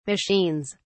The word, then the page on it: machines: British English